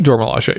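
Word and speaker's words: Dora Milaje: How do you say it? The Dor Milaje? – Dor Milaje. Yeah.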